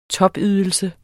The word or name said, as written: topydelse